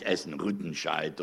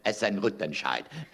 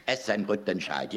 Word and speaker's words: Essen-Rüttenscheid: Da muss man nämlich die Silben immer gleich lang halten. Ganz wichtig. Es heißt nicht Essen-Rüttenscheid oder sowas. Kannst du nicht. Rütten. Essen-Rüttenscheid. Essen-Rüttenscheid. Jeder Vokal wird ausgesprochen.